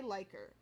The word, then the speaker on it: Leiker: So, Renee Leiker. I think I said her name right. It's L-E-I-K-E-R. – That sounds right.